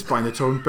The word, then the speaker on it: Binatone: Is that how you pronounce it? – It's Binatone, but you know,